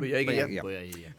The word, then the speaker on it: Boyega: The film tells the story of Fontaine, a drug dealer from the hood, played by John Boyega. Did I say that right? – Boyega.